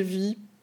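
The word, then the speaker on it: vi: Alors, je vais prononcer Vie parce que en français, ça se lit Vie. Et au travail, je dis Vie, donc on va dire Vie.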